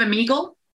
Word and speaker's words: Omegle: You know, have you heard of Omegle or Amigo? – No. How do you spell it? – O-M-E-G-L-E. – M-O-G-E-L-E? – O-M-E-G-L-E, Omegle. – O-M-E-G-L-E, Omegle.